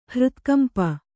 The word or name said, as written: ಹೃತ್ಕಂಪ